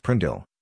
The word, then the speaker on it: PRNDL: The gear selector is often called the PRNDL, pronounced Prindl, regardless of the actual letters or numbers used.